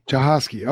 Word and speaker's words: Jahosky: And Mr. Jahosky, I always want to say Jahosky. I don't know. – Yeah. Do you ever get that? No, I get all sorts of exotic spellings and pronunciations. Everybody likes to add a C or a Z in there. And yes. My students, they really have been creative, but no, you nailed it. It's Jahosky. – Jahosky.